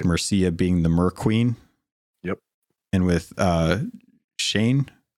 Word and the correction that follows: Shen: I always forget how they how it's pronounced. – Was he the was he Shen.